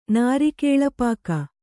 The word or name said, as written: ನಾರಿಕೇಳಪಾಕ